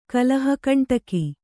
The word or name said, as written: ಕಲಹಕಂಟಕ